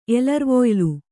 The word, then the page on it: ಎಲರ್ವೊಯ್ಲು: kannada